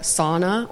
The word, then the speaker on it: sauna: So sauna or sauna is the correct way to say it.